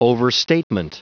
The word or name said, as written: overstatement